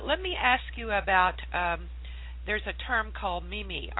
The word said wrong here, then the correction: meme: How do you pronounce it? – It's a meme.